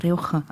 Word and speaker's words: Rioja: Und das andere wäre Rioja. – Rioja, also ist nicht so daneben gewesen.